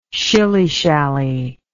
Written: shillyshally

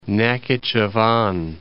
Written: Nakhichevan

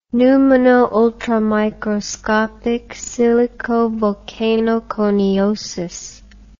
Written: pneumonoultramicroscopicsilicovolcanoconiosis

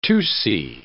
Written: Tutsi

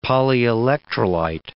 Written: polyelectrolyte

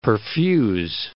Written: perfuse

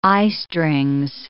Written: eyestrings

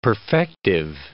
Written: perfective

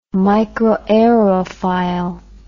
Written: microaerophile

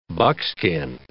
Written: buckskin

